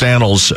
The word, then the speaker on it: annals: You can go back in the annals, it's pronounced annals, of history, and there was a dome stadium that was proposed for Edmonton.